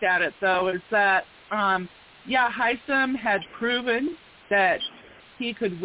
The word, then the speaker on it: Hisam: How is it pronounced? – Hisam? I just say Hisam.